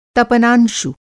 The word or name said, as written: तपनांशु